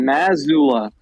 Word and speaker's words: Mazzulla: Mazzulla.